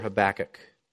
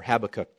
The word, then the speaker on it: Habakkuk: It's right after Micah and before Habakkuk, or Habakkuk, depending on where you put the emphasis on your syllable.